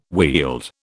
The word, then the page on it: Weald: British English